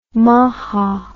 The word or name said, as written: maja